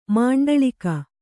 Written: ಮಂಡಳೀಕ

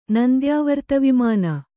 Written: ನಂದ್ಯಾವರ್ತವಿಮಾನ

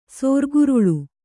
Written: ಸೋರ್ಗುರುಳು

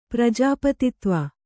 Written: ಪ್ರಜಾಪತಿತ್ವ

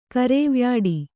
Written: ಕರೇವ್ಯಾಡಿ

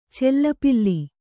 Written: ಚಲ್ಲಪಿಲ್ಲಿ

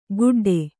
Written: ಗುಡ್ಡೆ

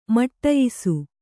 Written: ಮಡಿಯಿಸು

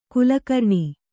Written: ಖಲಕರಣ

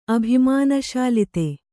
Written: ಅಭಿಮಾನಶಾಲಿತೆ

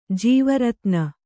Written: ಜೀವರತ್ನ

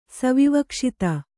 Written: ಸವಿವಕ್ಷಿತ

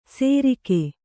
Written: ಸೇರಿಗೆ

